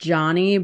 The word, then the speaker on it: Jawny: Johnny, but his name is spelled J-A-W-N-Y. So, but John, you know, Johnny, but pronunciation is in like Johnny Utah. That's literally his like Instagram handle is like J-A-W-N-Y Utah, Johnny Utah.